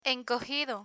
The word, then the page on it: encogido: Spanish